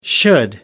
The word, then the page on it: should: American English